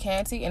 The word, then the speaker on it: Kante: And my Facebook name is Fanta Canty, and it's spelled F-A-N-T-A. My last name is K-A-N-T-E.